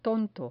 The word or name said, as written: tonto